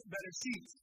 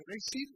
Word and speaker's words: Bereshit: That is pronounced as Bereshit. Okay? That means in the beginning. That's what it means. Bereshit is beginning.